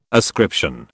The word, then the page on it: ascription: British English